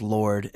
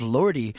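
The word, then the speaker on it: Lorde: her name is pronounced Lord and the E is silent. Mm-hmm, mm-hmm. I have everyone coming up to me going, what's up with this Lordy?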